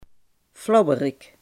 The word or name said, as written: flauwerik